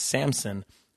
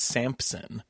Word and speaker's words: Samson: J-A-R-R-E-T-T. – And that sounds right. – My last name is also, my last name is Samson, but everyone thinks it's Samson. But there's no P, so it's S-A-M-S-O-N.